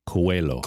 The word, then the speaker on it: Coelho: But yeah, you don't pronounce the H, so it's just Coelho.